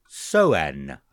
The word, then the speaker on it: Soen: Soen, I think is how it's pronounced. S-O-E-N.